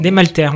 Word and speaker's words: Desmalter: Et alors, euh, pas n'importe quel ébéniste, parce que sur la page de Wikipédia qui en traite, c'est François Honoré Georges Jacob Desmalter, ou Desmalter, je ne sais pas comment on prononce. Desmalter, on dit.